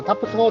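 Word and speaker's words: TAPS: それぞれね、英語の頭文字なんですけれども、えー、まあ、タップし、タップしで合ってるのかなまあ、このタップス法って読みますは、 to be、理想、 as is、まあ、現状、プロブレム、問題、ソリューション、解決ということで、頭の頭文字を取ってタップする。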